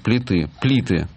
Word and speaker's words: плиты: Плиты, да. плиты с ударением на «и». Так во Львове называли пластинки.